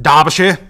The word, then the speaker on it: Derbyshire: 5th is Kate Butch from Derbyshire, or as they pronounce it, Derbyshire.